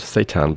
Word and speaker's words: Seudan: It's pronounced Seitan, but spelled S-E-U-D-A-N.